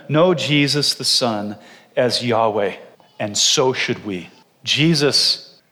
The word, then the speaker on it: Yahweh: We know it's not Jehovah. There's just no J in Hebrew. Yahweh, understanding that Yahweh is the covenant name of the God of Israel.